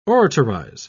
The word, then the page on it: oratorize: British English